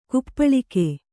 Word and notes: ಕಾಪಾಳಿಕ: kāpāḷika

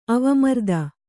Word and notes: ಅವಮರ್ದ: avamarda

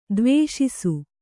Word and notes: ದ್ವೇಷಿಸು: dvēṣisu; Kannada

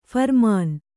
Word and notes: ಫರ್ಮಾನ್: pharmān

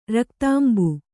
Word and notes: ರಕ್ತಾಂಬು: kannada

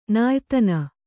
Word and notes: ನಾಯಿತನ: nāyi tana

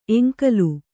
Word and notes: ಎಂಕಲು: kannada

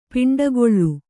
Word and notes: ಪಿಂಡಗಲ್: piṇḍagal

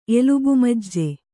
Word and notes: ಎಲುಬುಮಜ್ಜೆ: Kannada

